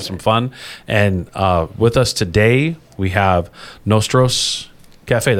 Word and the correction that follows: Nosotros Cafe: Did I pronounce that correctly? – Yes, Nosotros Cafe.